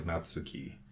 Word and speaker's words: Natsuki: I think that they have a soft stress in the middle syllable. – Okay. – If it's not that, it's the last syllable. – Yeah. – Yeah. – Yeah. – It's like Natsuki.